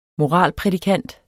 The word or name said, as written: moralprædikant